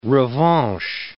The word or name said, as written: revanche